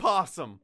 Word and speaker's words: opossum: Opossum. – It's opossum. – You don't the opossum is the O is silent, and so is the second P. Opossum.